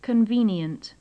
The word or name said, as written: convenient